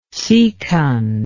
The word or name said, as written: secund